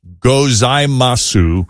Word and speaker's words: gozaimasu: So gozaimasu is spelled G-O-Z-A-I-M-A-S-U, but the U is sort of silent. It's not technically silent, but it's sort of like a vocal drop-off that they don't really pronounce.